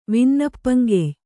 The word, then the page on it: ವಿನ್ನಪ್ಪಂಕೆಯ್: vinnappam key/vinnappankey